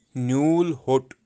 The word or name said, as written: nyulhot